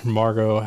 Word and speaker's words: Margot: you've got Thor and you've got Mar- Margot.